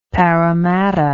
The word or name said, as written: paramatta